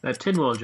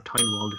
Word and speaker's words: Tynwald: Tynwald, John. Or Tynwald, if you prefer.